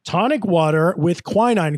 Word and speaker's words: quinine: Quarinine? – Oh, boy. You're almost there. You just said it wrong. – Quinine? – Quinine, Michael. Quinine.